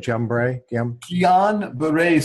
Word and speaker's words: Guillain-Barré: Guillain-Barré syndrome. It's actually two French doctors, Guillain or Guillain, which is the French for William, and the other doctor's name was Barré, like Barry.